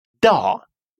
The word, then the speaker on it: dag: Да, день. Да. Обратите внимание, что финальное «ге» в этом слове не читается.